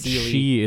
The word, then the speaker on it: Sidhe: They pronounce it she in the show, but... – Okay, different... Okay, so that's spelled, I believe, S-I-D-H-E, which is the more Welsh version of it.